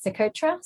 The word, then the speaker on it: Socotra: So, how do you pronounce it? Socotra? – Exactly. Yes. Yes.